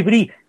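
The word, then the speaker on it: Brus: Louis Brie es, se escribe Bruce,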